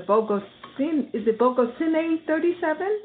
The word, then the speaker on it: Bogocine: Well, now your film, AGATHA, will have its official release in conjunction with the screening down in Bogota, Colombia, at the Bogocine 37? Am I pronouncing it correctly? – Mm-hmm. – Okay. – Yes, correct.